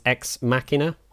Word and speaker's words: Ex Machina: Is there a canon pronunciation for this title? – I say Ex David? Machina because Deus Ex Machina. – It's the more sort of Latin type pronunciation.